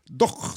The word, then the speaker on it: doch: Du, hur uttalar du dosch? Doch, säger jag. Doch, långt bak. – Doch, ja, det är rätt.